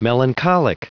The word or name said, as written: melancholic